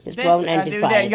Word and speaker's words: Dethrone and Defy: Jag har valt Dethrone and Defense. Dethrone and Defy.